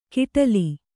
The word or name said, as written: ಕಿಟಲಿ